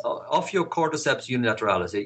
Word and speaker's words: Ophiocordyceps unilateralis: Ophiocordyceps unilateralis? – Yes, Ophiocordyceps unilateralis. You said it correctly.